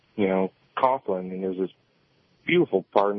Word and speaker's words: Coughlin: This was Father Coughlin, that's how you say his name.